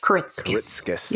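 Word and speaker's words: Kuritzkes: How do you say Karitzkis. His last name? – Karitzkis. – Yeah.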